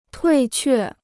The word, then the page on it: 退却: tuì què